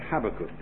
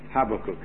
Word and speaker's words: Habakkuk: You might like to come to Habakkuk, or if you prefer to pronounce it that way, Habakkuk. Habakkuk is how I say it.